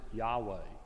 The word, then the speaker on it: Yahweh: We pronounce it Yahweh, the highest name that God gave himself.